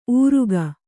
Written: ಊರುಗ

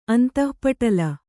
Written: ಅಂತಃಪಟಲ

